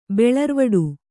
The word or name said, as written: ಬೆಳರ್ವಡು